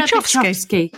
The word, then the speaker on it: Byczkowski: Hannah Byczkowski.